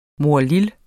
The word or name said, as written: morlille